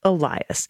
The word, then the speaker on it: Elias: I believe the correct pronunciation of our intrepid voting rights lawyer is Mark Elias, as in thank God for Mark Elias.